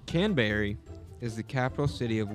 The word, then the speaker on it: Canberra: Canberra. It's spelled Can B E R R A.